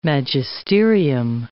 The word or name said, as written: magisterium